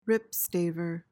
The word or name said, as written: ripstaver